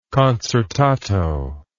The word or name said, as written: concertato